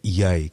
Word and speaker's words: Ye: escreve-se Y, mas uh, segundo sei, corrijo me Rui se estiver a dizer mal, mas creio que é Ye que é um, pronunciado.